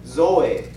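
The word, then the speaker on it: Zoe (ζωή): 第一个词就是刚刚我们在这里读到的，这个生命的发音呢比较像做 Zoe，它的意思是一段过程，你活的一段历程。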